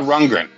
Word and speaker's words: Rundgren: Um, anyway, so Todd Rundgren, that is his name.